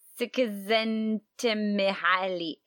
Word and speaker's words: Csikszentmihalyi: Mihaly Csikszentmihalyi.